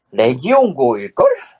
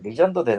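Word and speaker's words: Legion Go: 예, 한국에선 리전? – 리전이라고 읽네요.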